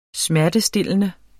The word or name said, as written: smertestillende